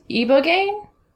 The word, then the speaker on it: ibogaine: And is it pronounced Ibogaine? – Yes, that's what we're looking at.